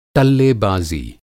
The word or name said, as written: टल्लेबाज़ी